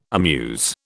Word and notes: amuse: British English

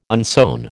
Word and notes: unsewn: American English

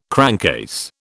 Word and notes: crankcase: British English

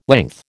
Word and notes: length: American English

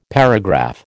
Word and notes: paragraph: American English

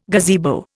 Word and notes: gazebo: Indian

